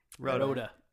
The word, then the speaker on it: Rhoda: Rhoda.